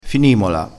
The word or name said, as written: finimola